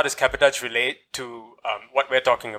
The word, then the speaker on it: cabotage: Okay, tell us actually what is cabotage. – Okay, so cabotage. – Did I say it right? Cabotage? – Cabotage. Cabotage. Okay.